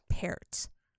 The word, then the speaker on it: Peart: It's Peart. So Okay. Don't come for me. – I won't. – Because I will give it right back to you. That's how you pronounce his name. – Okay. I mean, she's not wrong. I have heard him say his own name.